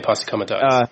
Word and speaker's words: posse comitatus: Posse comitatus. I don't think I'm saying it right. – Yeah, posse comitatus.